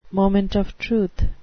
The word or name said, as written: moment of truth